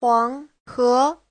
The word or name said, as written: huang2he2